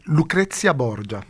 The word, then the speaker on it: Lucrezia Borgia: Eh bien, nous allons pouvoir écouter un extrait de la production de Lucrezia Borgia. Est-ce que je prononce bien ? – C'est Lucrezia Borgia. – Vous avez vu comme je ne prononçais pas bien.